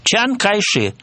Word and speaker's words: Чан Кайши: Чан Кайши – это гуандунское произношение. А если он приезжает в Пекин, если его называют по его иероглифам, он звучит Дзян Цзэши. Согласитесь, что Чан Кайши и Дзян Цзэши – это разные звучания.